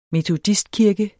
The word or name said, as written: METODISTKIRKE